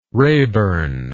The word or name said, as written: raborn